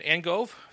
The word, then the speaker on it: Angove: In the scheme of YouTube channels, we have Brad Angove.